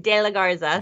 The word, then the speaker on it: De La Garza: De La Garza.